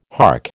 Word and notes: hark: American English

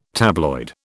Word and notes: tabloid: British English

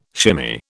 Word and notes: shimmy: British English

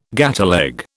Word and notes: gateleg: British English

